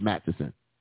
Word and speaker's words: Matteson: Yeah, third grader, nine years old, got shot today in Matson, Illinois. Or, I'm sorry, let me say, is it Matteson? I think it's Matteson. Because we always say Matson, but I think it's pronounced Matteson. I don't want to be one of them people that, you know, saying stuff wrong, at least not on purpose.